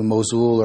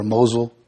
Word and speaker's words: Mosul: And then we moved up to Mosul or Mosul. Both pronunciations are correct.